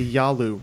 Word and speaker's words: Yalu: The Yalu River.